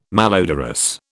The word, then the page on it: malodorous: British English